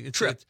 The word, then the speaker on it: trippe: Trip. – TRIPPE. Belgian trip. – Okay. – Check it out. – I will. – I would pronounce that tripe for some reason. – Tripe. Trip.